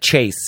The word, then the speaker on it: Chase: You know, when I'm doing call-in orders for places around town, I'm always like Chase, C-H-A-S-E.